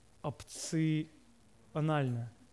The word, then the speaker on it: опционально: Писание, но обязательно, традиция имеет человеческое происхождение, ее исполнение опциально, опциально, опционально. Опционально. Простите, пожалуйста, ударение поставил не там, где надо.